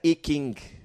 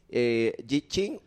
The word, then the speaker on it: I Ching: Se pronuncia I Ching. – I Ching. – Ajá. – I Ching tiene mis respuestas. – Se llama... Le dicen I Ching. Aquí está con la G, pero es Yi Ching o I Ching.